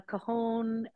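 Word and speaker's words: cajón: And is it pronounced cajon? Is that how you say it? – Yeah, and I don't really play it in public, by the way. yeah, a cajon, another type of percussion instrument similar to that, a djembe.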